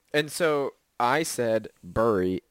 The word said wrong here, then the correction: Bury: and everybody was like, that's not how you pronounce that word. It's pronounced Barry. And I'm like, where's the A in this word?